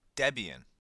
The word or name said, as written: Debian